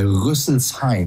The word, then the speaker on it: Rüsselsheim: You're going to Das Ride in Rüsselsheim, if I'm pronouncing that correctly. – Yeah, Rüsselsheim.